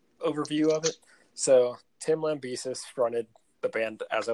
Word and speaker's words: Lambesis: Is that how you say his name? – I think it's Lambesis.